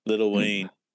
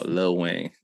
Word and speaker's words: Lil Wayne: Little Wayne. – I know. White people would pronounce the whole word like Little Wayne. No, Lil Wayne. It's not little. No, no, Lil.